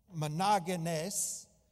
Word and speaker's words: monogenes: And forgive my pronunciation, but the best attempt I can is monogenes, spelled M-O-N-O-G-E-N-E-S, for those that are taking notes.